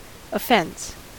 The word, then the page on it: offense: American English